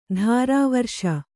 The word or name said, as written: ತಿರುವೇರಿಸು